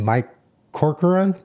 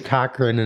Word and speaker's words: Corcoran: I keep saying Cochran, and it's like, no, that's not Cochran. It's Corcoran. Anyways, this is Mike from Music Submit.